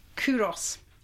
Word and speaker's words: Culross: So the first one we've got is called Kuros, which is spelt Culross in Scotland. – Oh, okay. – So you would never get Kuros from Culross, would you? – Yeah, my geography's poor on a good day.